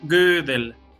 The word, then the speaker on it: Gödel: en, alemán, la O con, con, una umlaut, con dos puntitos, es entre una O y una E, como en, francés Pasteur, es Gödel. – Em-, sin, embargo, Gödel es checo.